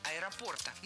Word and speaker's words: аэропорта: Радует, что ударение в существительном поставлено верно – «аэропорта».